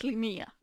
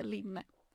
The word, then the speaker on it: Linné: Och jag vet Linné, inte Linne.